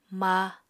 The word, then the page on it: ma: apatani